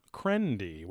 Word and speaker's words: Qrendi: This place is called the Island of Krendi, which is spelled Q-R-E-N-D-I.